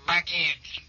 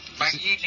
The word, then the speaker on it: Begin: Abbiamo visto in queste poche minuti fa il presidente egiziano Sadat scendere dall'aeroplano a venire al conto del primo ministro ebreo Begin, secondo della pronuncia inglese. Begin in italiano, Begin secondo la pronuncia inglese.